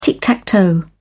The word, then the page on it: tic-tac-toe: British English